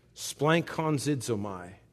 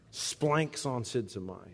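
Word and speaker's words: Splankonzidzomai (σπλαγχνίζομαι): Splankonzidzomai. Pronounced exactly like they used to do it in the old country. Splanks on sidzomai.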